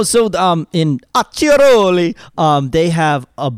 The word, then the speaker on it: Acciaroli: It's whenever you have a C next to an I, it's pronounced chi. When you have a C-H-I, it's pronounced key. So you have two C's. It's A-C-C-I, so Acciaroli, kind of like Gucci.